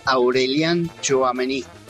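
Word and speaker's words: Aurélien Tchouaméni: Fer, yo te digo cómo se pronuncia, Aurelien Tchouaméni, jugadorazo.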